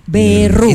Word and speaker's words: Berrú: Con B larga. E-R-R-U. Acento en la U. – Berrú.